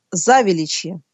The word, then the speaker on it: Завеличье: Если вы окажетесь за рекой Великой от Кремля, это будет район, который называется Завеличье. Причем ударение на первый слог, заметьте.